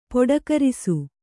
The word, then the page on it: ಪೊಡಕರಿಸು: poḍakarisu